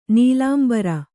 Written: ನೀಲಾಂಬರಿ

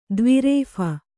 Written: ದ್ವಿರೇಫ

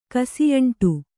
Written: ಕಸಿಯಂಟು

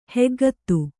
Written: ಹೆಗ್ಗತ್ತು

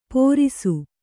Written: ಪೋರಿಸು

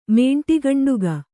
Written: ಮೇಂಟಿಗಂಡುಗ

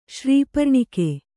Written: ಶ್ರೀಪರ್ಣಿಕೆ

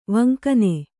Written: ವಂಕನೆ

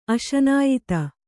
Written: ಅಶನಾಯಿತ

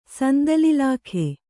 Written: ಸಂದಲ್‍ಇಲಾಖೆ

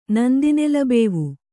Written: ನಂದಿನೆಲಬೇವು

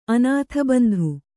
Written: ಅನಾಥಬಂಧು

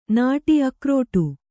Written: ನಾಡಿ

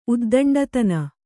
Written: ಉದ್ದಂಡತನ

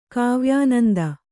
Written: ಕಾವ್ಯಾನಂದ

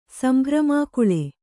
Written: ಸಂಭ್ರಮಾಕುಳೆ

